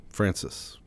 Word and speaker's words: Francis: Francis.